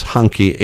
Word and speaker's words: Hanke: But Hanke, no, it's not Hokey Henry calendar. This is Hanke, H-A-N-K-E. Hanke. Maybe if it's German, it's pronounced that way.